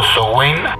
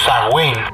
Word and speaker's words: Samhain: Podemos rastrear sus orígenes hasta el festival del Samhain, pronunciado Sowain o Samhain, en el que se celebra el Año Nuevo Celta.